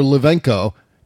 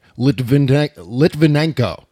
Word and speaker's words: Litvinenko: Litvinenko in 2006. I think I just butchered that name. Litvinenko.